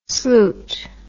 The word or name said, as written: sloot